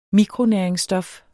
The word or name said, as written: mikronæringsstof